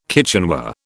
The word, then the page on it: kitchenware: British English